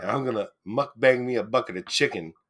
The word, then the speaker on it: mukbang: Oh, I learned that there's a Korean audio podcast that is pronounced mukbang, but it's spelled like mukbang.